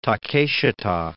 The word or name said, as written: Takeshita